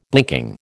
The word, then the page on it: blinking: American English